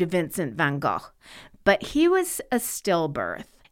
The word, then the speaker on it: Van Gogh: Of course, my last but not least is one of the most iconic artists that sparks fascination in just about everyone, Vincent Van Gogh. Now, only Americans say Van Gogh, by the way. The correct pronunciation is Van Gogh. And even in England, they'll say Van Gogh.